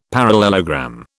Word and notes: parallelogram: British English

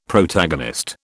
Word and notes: Protagonist: British English

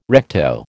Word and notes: recto: American English

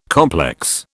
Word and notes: complex: British English

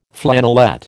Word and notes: flannelette: American English

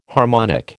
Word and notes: harmonic: American English

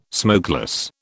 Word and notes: smokeless: British English

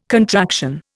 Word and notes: contraction: Indian

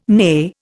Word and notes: neigh: Indian

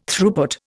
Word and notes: Throughput: Indian

